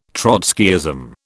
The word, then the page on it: Trotskyism: British English